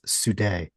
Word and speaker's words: Sude: I believe it's pronounced Sude. – Okay, but see, I wasn't sure. – I'm kidding. – Because spelled as is the supreme decider, which sounds like another president from the late 90s, early 2000s. – No, it's spelled S-U-D-E, supreme decider. Right. So Sudi is probably how it's pronounced, or it could be Sud.